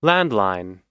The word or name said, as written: landline